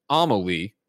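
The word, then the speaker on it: Amélie: Nika Ash writes in and says, Amelie is pronounced Amelie, emphasis on the first syllable.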